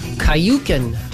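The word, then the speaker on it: Küken: Kayuken is how you pronounce the name of that band. K-U-K-E-N, and the U has an umlaut over it.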